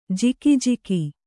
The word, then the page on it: ಜಿಕಿಜಿಕಿ: jikijiki